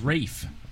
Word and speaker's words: Ralph: Why is his name spelled Ralph, but it's pronounced Ray? – It's Rafe.